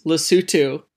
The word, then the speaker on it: Lesotho: The correct way is Lesotho. It is spelled L-E-S-O-T-H-O, though, so hence some of the confusion. But in Sesotho, which is the language spoken there, the T-H kind of makes the S sound. – The T-H becomes an S. – So, becomes a T. Sorry, the H is silent.